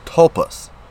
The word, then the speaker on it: tulpas: So, next is thought forms and tulpas. T-U-L-P-A-S. Tulpas. I said it right.